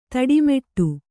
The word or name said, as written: ತಿಟ್ಟಮಿಡು